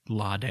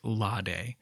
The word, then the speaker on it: Lade: I do know that the correct pronunciation of the island's name is Lade, but there is a small, maybe immature part of me that wishes it was Lade, so that kind of stupid rhyme above would work out to be "They pitched their tents on the island of Lade in the shade."